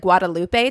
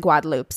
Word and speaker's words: Guadeloupe: So when I read this, it looks like Guadalupe to me. It's spelled like Guadalupe. But when I listened to the French Creole pronunciation of the island, they pronounce it Guadeloupe. So that's how I will pronounce it.